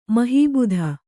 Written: ಮೊಹಬತ್

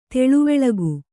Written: ತಿಳಿವಳಿಕೆ

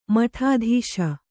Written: ಮಠಾಧೀಶ

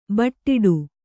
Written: ಬಡ್ಡು

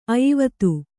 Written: ಅಯಿವತ್ತು

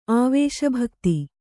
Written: ಆವೇಶಭಕ್ತಿ

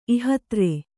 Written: ಇಹತ್ರೆ

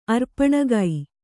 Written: ಅರ್ಪಣಗೈ

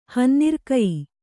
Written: ಹನ್ನಿರ್ಕಯಿ

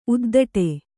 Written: ಉದ್ಧಟೆ

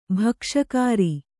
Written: ಭಕ್ಷಕಾರಿ

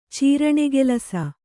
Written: ಚೀರಣೆಗೆಲಸ

